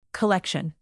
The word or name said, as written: collection